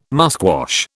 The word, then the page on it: musquash: British English